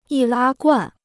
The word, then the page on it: 易拉罐: yì lā guàn